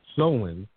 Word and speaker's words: Samhain: Samhain, S-A-M-H-A-I-N. The pronunciation is Samhain.